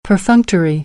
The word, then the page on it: perfunctory: American English